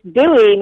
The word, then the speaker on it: Bowie: It is Bowie, Maryland, like David Bowie,